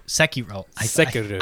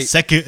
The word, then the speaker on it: Sekiro: Sekiro. – I've never had a problem. – It's because you're not saying it right. – Sekiro. – Sekiro. Your ancestors aren't happy that you're not saying it properly.